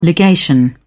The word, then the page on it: legation: British English